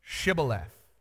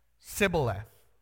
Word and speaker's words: Shibboleth: When they said no, they said to him, "Then say Shibboleth." And he said Sibboleth, for he could not pronounce it right.